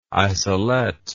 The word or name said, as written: isolette